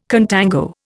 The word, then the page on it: contango: Indian